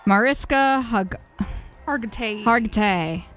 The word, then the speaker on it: Mariska Hargitay: And Jimmy Kimmel Live has, I always say her name wrong, but I love her deeply. Mariska Hargitay. – Yeah. You got it right.